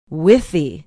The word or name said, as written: withee